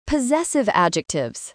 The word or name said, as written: possessive adjectives